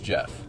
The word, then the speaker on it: Geoff: It was, the name was pronounced Jeff. – Okay. – Okay. – Was it like was it Jeff spelled with like a G? – G-E-O-F-F.